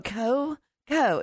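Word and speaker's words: Kho-kho: No, Co-Co.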